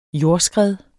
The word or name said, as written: jordskred